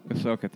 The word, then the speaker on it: Ahsoka: Ahsoka.